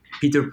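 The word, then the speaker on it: Piter: Or also Peter Punk, but Peter spelled with an I, P-I-T-E-R, and then Punk, P-A-N-K.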